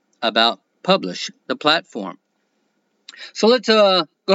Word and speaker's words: Publish0x: It is pronounced Publish, but it is spelled Publish0x.